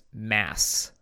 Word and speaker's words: Mads: Because I believe Mads is the actor, Mads Michaels. – So I believe the pronunciation is Mass.